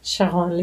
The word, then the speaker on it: Sharon: And of course, in French, my first name is Sharon, Sharon Lee. Wait, it's Sharon Lee.